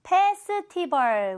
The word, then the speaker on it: Festival: 축제는 여러분, Festival, Festival, 우리가 많이 들어봤는데요. 아아, 그 발음 아니에요. Festival, Festival, Fall, Fall, 이렇게 끝나는 거 확인되시죠?